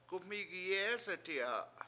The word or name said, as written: gum7IIgi7EEseti7a